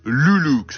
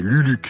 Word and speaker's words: Luluc: du nom de Luluk. Ça s'écrit L-U-L-U-C, Luluk si vous préférez.